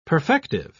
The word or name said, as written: perfective